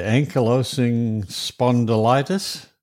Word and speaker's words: ankylosing spondylitis: Now, I'd have trouble pronouncing what you said you had, the ankylosing spondylitis.